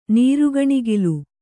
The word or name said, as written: ನೀರುಗಣಿಗಲು